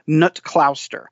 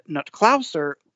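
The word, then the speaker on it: Knut Kloster: but I believe it is pronounced Nutklauster.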